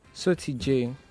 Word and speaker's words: Soty J: The Soti J, it's my nickname, and the J for Jumbo, Soti J. Then the Tattoo Lagos. – Oh, Soti. – Soti J. – Spell it. S-O-T-Y-J. Oh, Soti J.